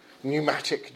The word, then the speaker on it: pneumatic: the air-powered pneumatic drill with the silent P, pneustos.